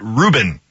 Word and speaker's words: Rubin: they didn't spell it ruin, they spelled it Rubin. Tom Rubin, like the sandwich.